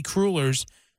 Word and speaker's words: cruller: What is going to be going off the shelves is the Krispy Kreme donut bites and the Krispy Kreme mini crullers. Did I say that right? Crullers? – Mm-hmm. I think it's cruller.